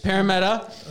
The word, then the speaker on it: Parramatta: When did Parramatta? – That's how you say it. – Parramatta.